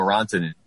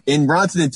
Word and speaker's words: Rantanen: He pronounces Rantanen with like 7 L's, and I have never seen any L's in Rantanen's name.